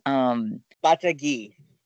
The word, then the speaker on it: Batagui: Batagui. – Batagui. Okay, so yep. Emphasis on the last bit of it.